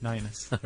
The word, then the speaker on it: Ninas: He even spelled out how to say it, Ninas.